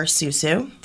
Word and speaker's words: PSU: The walkout was planned by members of the Portland State University Student Union, or SUSU, the P is silent.